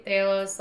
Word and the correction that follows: Talos: Like, he was amazing. – Talos. – I can't say his name ever. – Why do you put an H in it? – I don't know.